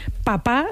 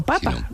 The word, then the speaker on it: papa: Porque la acentuación histórica, o sea, la manera histórica que en español hemos tenido de llamar a nuestros padres ha sido papa, mi papa.